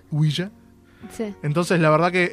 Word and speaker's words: Ouija: ¿Cómo se pronuncia Ouija? – Ouija.